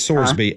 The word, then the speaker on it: Sorsby: Is it Thorsby or Sorsby? – I've Sorsby. – Heard both pronunciations. – It's Sorsby. S-O-R-S-B-Y-S. Sorsby.